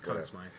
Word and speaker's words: Conn Smythe: But another interesting thing about Ron Hextall and the history of hockey, he is a Conn Smythe winner. I'm sorry if I'm not pronouncing that right, or Conn Smythe. – It's Conn Smythe.